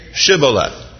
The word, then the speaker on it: Shibboleth: If that person says no, they say, "Oh yeah, then say Shibboleth." Because it says the Ephraimites couldn't pronounce SH, and they would go Sibboleth.